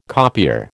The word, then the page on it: copier: American English